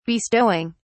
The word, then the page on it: bestowing: British English